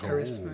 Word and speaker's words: charisma: where where I believe that you know how do you say this? Hmm. Carisma. Yeah, carisma. Oh. – Charisma.